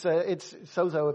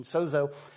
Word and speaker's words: sozo: Sozo is the Greek word, and it's sozo, if you go with the common pronunciation, sozo.